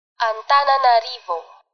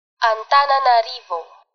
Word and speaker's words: Antananarivo: I'm not so good at saying that right, so I'm going to let you listen to it spoken properly, and then you can try. – Antananarivo. Antananarivo.